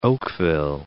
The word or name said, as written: Oakville